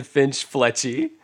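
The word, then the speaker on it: Finch-Fletchley: Justin Flinch, he made it say it wrong. Justin Finch Fletchy.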